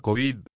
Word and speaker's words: COVID: Así, lo normal es pronunciar COVID. Es la COVID-19 o el COVID-19,